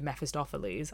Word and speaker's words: Mephistopheles: or and comparing him to Mephistopheles. I'm so glad I said that right.